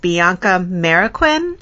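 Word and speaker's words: Bianca Marroquín: I believe her name is pronounced Bianca Mariquin.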